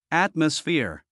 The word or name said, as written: atmosphere